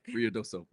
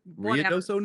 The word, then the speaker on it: Ruidoso: Ruidoso. Ruidoso, New Mexico.